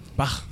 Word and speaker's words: Bach: Bach.